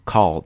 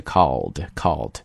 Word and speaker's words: culled: 然后后面跟了一个伴随 leading to more than 1.2 million pigs being culled, 那么在中国境内呢已经有 120 万头猪啊已经被捕杀掉了，在这里这个 culled 就读出来了，对吧，不要读成 cull, 而是 culled。